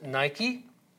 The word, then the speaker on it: Nike: You'll notice that there's one there, the goddess called Nike, or as we pronounce it, Nike.